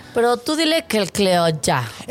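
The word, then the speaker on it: Clíodhna: Que no, seguro lo estoy pronunciando mal, pero se escribe C-L-I-O-D-H-N-A. – Cliodna. – Entonces, yo creo que sí es, pues, Cliodna.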